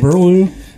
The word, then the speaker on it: Burlew: Ben Burlew. – Yeah. What? – Is it Burlew? Is that how you say your last name? – Yeah, Burlew.